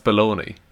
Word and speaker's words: bologna: Okay, how would you pronounce it? B-O-L O-G-N-A. – That's pronounced baloney.